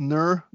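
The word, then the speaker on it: Knur: I'm going to go with Nur, the silent K.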